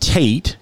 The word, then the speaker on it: Tet: The Hebrew letter is spelled T-E-T, but it's pronounced Tate, and it is T as in tall, a Tate.